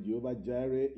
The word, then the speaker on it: Jehovah Jireh: Okay, Jehovah Jireh is the correct pronunciation of that.